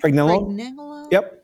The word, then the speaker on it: Pregnenolone: Pregnenolone.